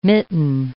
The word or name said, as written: mitten